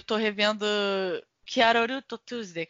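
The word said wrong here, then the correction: Carole & Tuesday: como é que fala? Carol and Tuesday.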